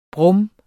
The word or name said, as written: brum